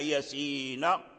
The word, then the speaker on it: ياسين: ياسين يكتب الياء والسين، لكن في النطق تنطق يا، أي بيا وألف يا، ثم السين تنطق بثلاثة أحرف سين، بالسين والياء والنون، والياء حرف مد التقت مع النون الساكنة فهي من باب المد اللازم.